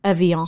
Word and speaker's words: Evian: That's weird, too, because they pronounce, like, all the letters in that word are pronounced, which is not something you get a lot we're probably from not. France. I don't think we're saying it right. – I think Evian. – Yeah, it's probably. – Evian. – Evian. – So the N is gone.